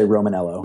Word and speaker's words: Romaniello: No, we don't use the I. The I is, it's a silent I. I assume many, many generations back it was Romaniello, but we're particularly Americanized, and we just say Romaniello.